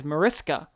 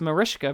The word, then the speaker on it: Mariska: And she also spoke, it's also great that this movie is finally letting people know how to pronounce her name, because you see it and you say it phonetically as Mariska. – Right. – But it's Mariska because it's Hungarian.